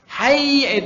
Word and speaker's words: hayya: Jadi hayya 'alas shalah misalnya. Hayya itu pendek. Tapi ketika dipanjangkan hayya, artinya sudah berubah.